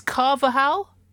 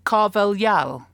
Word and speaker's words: Carvalhal: Because Paul in Philadelphia, go Eagles, some of us were there, don't like to brag, says, you've all been saying Carlos's name as Carvajal, but that is a Spanish pronunciation, and Carvajal is Portuguese. The proper way to say his name is Carvalhal.